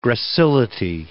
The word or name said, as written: gracility